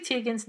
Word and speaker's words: Teigen: And uh, Chrissy Teigen's apology to her, and now Chrissy Teigen's name is pronounced widely pronounced Teigen, but she actually prefers Teigen.